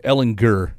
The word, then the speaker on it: Ehlinger: I believe it's a hard G. Ehlinger.